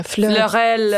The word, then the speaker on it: Fleurel: Pastelli skulle jag välja Sweet Nathalie, Labyrinth, Fleurel, uttalar jag det rätt nu? – Fleurel.